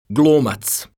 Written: glumac

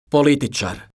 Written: politicar

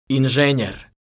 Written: inzenjer

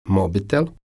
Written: mobitel

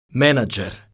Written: menadzer

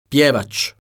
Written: pjevac

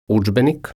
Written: udzbenik

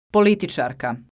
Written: politicarka

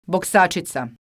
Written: boksacica

